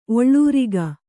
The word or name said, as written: ಒಳರೋಗಿ